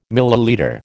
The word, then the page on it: millilitre: American English